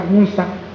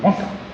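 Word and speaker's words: Münster: ¿Pero así se llama? ¿Qué se dice así? Munsta. Sí, porque tú sabes alemán. Soy en alemán, Munsta.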